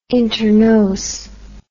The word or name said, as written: internos